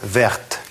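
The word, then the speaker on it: verte: В результате добавления «э» в женском роде читается «верт» – зеленая.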